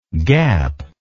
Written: gapless

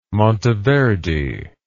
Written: Monteverdi

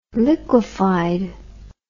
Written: liquefied

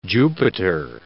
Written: Jupiter

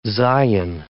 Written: zayin

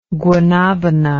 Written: guanabana

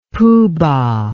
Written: poohbah

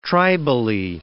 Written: tribally

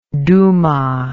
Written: Douma

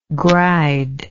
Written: gride

